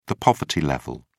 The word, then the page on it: poverty level: British English